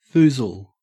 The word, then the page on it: foozle: Australian English